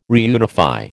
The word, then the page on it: reunify: American English